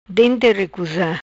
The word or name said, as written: Dente ricusa